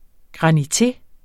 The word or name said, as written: GRANITE